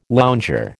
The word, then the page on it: lounger: American English